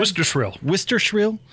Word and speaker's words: Wistrcill: And Hans and Scotty had their commissioner on, Tom Wistrcill. Am I saying that right? – Wistrcill.